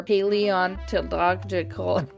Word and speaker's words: paleontological: Under Chilean law, it is illegal to, quote, carry out archaeological, anthropological, or paleontological... Help me out. How do you say that? – I have no idea what word you're trying to say. Paleontological? – Yeah, there you go.